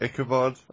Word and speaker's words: Ichabod: That's how you pronounce it, right? Ichabod? – Yeah, you got it right.